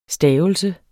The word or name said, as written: STAVELSERNE